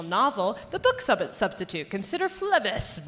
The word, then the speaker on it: Phlebas: For Firefly, for example, they say you may want to check out, consider, I can never say the Phlebas, Ian M. Banks. – I think it Phlebas? – Phlebas. – Ian Banks. – Phlebas. Phlebas. Yeah. Phlebas. – Yeah. Phlebas. Consider Phlebas. Consider learning how to pronounce this and then ordering it at your bookstore.